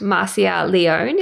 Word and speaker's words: Marcia Leone: You are at Marcia Leone.